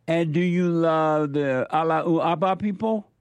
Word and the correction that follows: Allahu Akbar: Allahu Akbar.